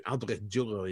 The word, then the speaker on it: Albrecht Dürer: Albrecht Dürer. I think I have pronounced that properly. – Very good. Yes, very good. Brought up the German in you. Albrecht Dürer, indeed.